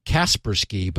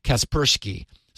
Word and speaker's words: Kaspersky: I heard you putting the accent on the second syllable, and I went and checked, Leo, and you're exactly right. I have been always saying Kaspersky, but it's Kaspersky.